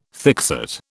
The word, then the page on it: thickset: British English